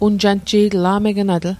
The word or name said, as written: a'megenatl